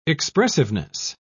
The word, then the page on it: expressiveness: British English